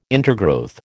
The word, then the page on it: intergrowth: American English